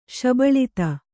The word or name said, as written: ಶಬಳತೆ